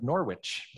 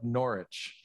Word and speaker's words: Norwich: When I was from the Midwest, I naturally would have pronounced this Julian of Norwich. But back in England, they forget that W, Julian of Norwich.